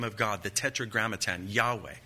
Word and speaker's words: Yahweh: The first word, Lord, is the tetragrammaton, the proper name of God, Yahweh. Most popular pronunciation is Jehovah.